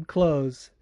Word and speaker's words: Kloz: Next question is from Kim Close, at KimClose_4. Close is spelled KLOZ, and it took me 5 podcasts to pronounce it right.